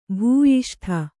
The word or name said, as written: ಭೂಯಿಷ್ಠ